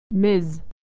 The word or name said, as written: Ms.